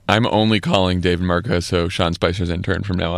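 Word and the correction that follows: Martosko: Martosco. I guess it's Martosco is how you say it.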